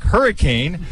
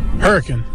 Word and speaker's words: Hurricane: It looks like hurricane, but it is pronounced hurricane.